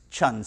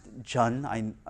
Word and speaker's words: Chun: And we always, my last name is Chun, C-H-U-N. But it's a, you know, I'm Korean ethnicity-wise, and in the Korean language, it's pronounced Jun. It's like J-U-N is the actual phonetic pronunciation of it, but my last name in English is C-H-U-N, so it's Chun.